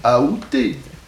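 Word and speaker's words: aoûté: La prononciation qui est considérée comme correcte, c'est la prononciation avec A. Aouter.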